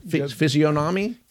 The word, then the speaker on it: physiognomy: How do you say that? Physiognomy? – Physiognomy, yes.